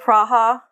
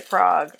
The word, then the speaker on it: Prague: What did she call it? – Praha. – Praha. – And he's like, oh, if you're saying it in English, it's appropriate to say Prague.